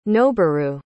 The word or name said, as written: noburu